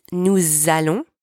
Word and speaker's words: allons: Make sure you use the liaison properly in the plural forms. It's nous allons, not nous allons. You've got to link the two words together with the Z sound.